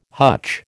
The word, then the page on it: hutch: American English